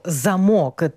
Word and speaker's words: замок: а в українській мові це теж це слово «замок». Трішечки тут наголос у нас перемістився на другий склад.